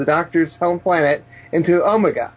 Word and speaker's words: Omega: That's right, not Omegar. – Omega. – Omega, okay. – Omega, that's what Omega. Debbie says, doesn't she? – Omega is how Americans would play. Americans say Omega, yeah.